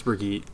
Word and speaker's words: Brigitte: No, it's B-R-I... What is it? – It's pronounced Brigitte. – Is it Brigitte? – Yeah. I don't know how to spell it. It's B-R-I-G-I-T-T-E.